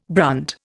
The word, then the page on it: brunt: Indian